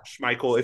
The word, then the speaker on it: Schmeichel: May have pronounced the name wrong. – Schmeichel.